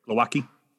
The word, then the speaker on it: Glowacki: I can never say his name. Glowacki? Yep. Glowacki? Glowacki. How do I say it? – Glowacki.